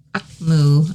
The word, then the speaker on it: AKMU: Is it AKMU or is it AKMU? ACMU. I looked it up. That's what they said. That's how you pronounce it.